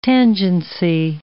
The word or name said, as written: tangency